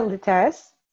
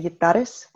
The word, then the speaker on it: Luteras: So it's Talia Luteras. It's Spanish, so you can also say Yutares, but where my family's American, we just have a Spanish last name.